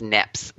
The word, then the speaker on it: Niepce: I think it's Niepce, but it's N-I-E-P-C-E.